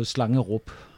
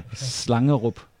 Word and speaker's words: Slangerup: Det er Slangerup, så ja. Det kan I godt udtale. – Kamaloso? – Ja, netop. – Si det en gang til. – Slangerup. – Slangerup.